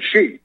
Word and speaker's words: Xi: I think the X is pronounced like a Z. She. I think it's pronounced Xi Jinping.